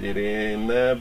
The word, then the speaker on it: Irene: Hát mindig azt mondja egyébként, hogy Iréne, bár kicsit furcsa.